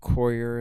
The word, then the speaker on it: coir: This is cocoa. Coir is how it's pronounced.